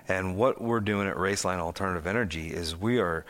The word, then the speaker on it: Roeslein: pronounced Raceline but spelled R-O-E-S-L-E-I-N, in case anybody wants to look it up.